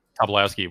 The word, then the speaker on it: Tobolowsky: But when I think of the big scenes, like when you have Stephen Tobolowsky, or however you say his name, Tobolowsky,